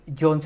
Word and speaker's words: John: Mi nombre es John Vargas Fotografía. John se escribe J-O-H-N, Vargas Fotografía.